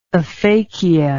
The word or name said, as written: aphakia